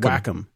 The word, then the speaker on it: Wacom: When I go to SIGGRAPH, I'd go to the booth and they would always chastise me and say it's called Wacom.